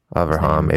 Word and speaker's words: Avraham: Avraham is how you say Avraham, Abraham.